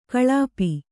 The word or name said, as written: ಗಳಪು